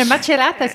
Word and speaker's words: Macerata: You know, another thing about Florence, we were getting the bus from Macerata. Sorry, apologize for the pronunciation. – No, Macerata's good.